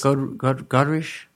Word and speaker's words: Godrèche: Godrèche. – Godrèche? – Godrèche. – She's French. There's an accent in her name.